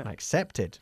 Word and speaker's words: accepted: ¿Por qué pronuncias este id? Porque el verbo to accept acaba en t, ok? Igual que started. I start, yesterday I started. I accept, yesterday I accepted, ok? – Okay.